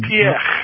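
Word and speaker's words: Piëch: Piech. – Piech.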